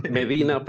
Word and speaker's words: Medina: Hay un vecindario aquí en el área de Seattle que se escribe igual Medina, que lo pronuncia Medina, y es donde viven los, no millonarios, los billonarios del país, ¿no?